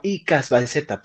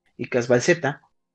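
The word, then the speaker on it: Icazbalceta: que en aquel entonces era un tipo de apellido Iscalbalseta Iscalbalseta o Icasbalceta, no, Icasbalceta, perdón, así se, pronuncia, Icasbalceta,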